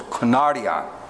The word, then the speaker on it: kunarion: Okay, now, in the Greek, I'm going to try to pronounce it as kunarion. K-U-N-A-R-I-A-N. You can just look this up in the Blue Letter Bible in a concordance. Kunarion. That's translated little dogs. It's kunarion in the Greek, and it literally means little puppies.